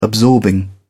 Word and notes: absorbing: British English

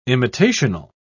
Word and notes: imitational: British English

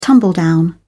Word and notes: tumbledown: British English

